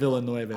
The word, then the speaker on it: Villeneuve: So how do you pronounce the Arrival? – It's Arrival by Denis Villeneuve. – I can't pronounce it. – Villeneuve.